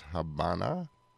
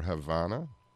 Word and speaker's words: Habana: And the name of that track was Habana, or Havana, as we Americans pronounce it.